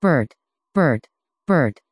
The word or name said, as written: bert